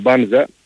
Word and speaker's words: Μπάνζα: Από τη μία υπάρχει ο Μπάνζα, και πλέον είναι οριστικό, τονίζεται στην πρώτη συλλαβή,